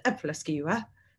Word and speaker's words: Æbleskiver: And so we always make this Danish Christmas treat called an Abelskiver. And when I was in Denmark, they pronounced it Æbleskiver, which is very different sounding.